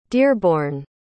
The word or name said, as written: dearbourne